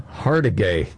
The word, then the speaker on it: Hargitay: Oh, it's Mariska Hargitay or how do you pronounce her name? – Hargitay. Nice one.